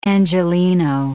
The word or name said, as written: Angeleno